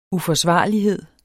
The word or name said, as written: uforsvarlighed